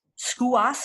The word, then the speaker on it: Sceva: I looked up the name Skuas, which is how it's pronounced.